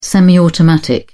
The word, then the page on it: semiautomatic: British English